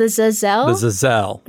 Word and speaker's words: Zazel: Emphasis on that second syllable. – It's like there's a hyphen between Z-A and Z-E-L. – The Zazel. – Yeah, or like the word gazelle.